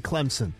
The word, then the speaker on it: Clemson: Clemson, you've got that number one spot, and there's no P in Clemson. Down in the South, people pronounce it with a P, and there is no P in it.